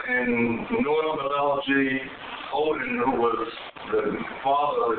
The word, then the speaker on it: Odin: Again, that was O-D-E-N, pronounced Odin.